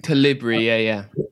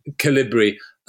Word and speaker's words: Calibri: The default font is, I don't know how you pronounce it, Calibri or Calibri? – Calibri, Calibri. Thank you.